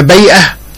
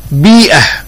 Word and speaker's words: بيئة: أحد المستمعين الكرام يسأل عن نطق كلمة البيئة، فيقول: نسمع بعض المتحدثين ينطقون هذه الكلمة بفتح الباء فيقولون بيئة، وبعضهم الآخر ينطقها بكسر الباء فيقول بيئة، فأي النطقين هو الصواب في اللغة؟ أقول إن الصواب هو نطقها بكسر الباء بيئة، أما نطقها بفتح الباء بيئة فخطأ بين.